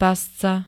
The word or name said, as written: pasca